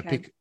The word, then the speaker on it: Picoult: I would go Pico. – Is that the right thing? – That so is, if I said believe that is the correct pronunciation. Picoult would be wrong.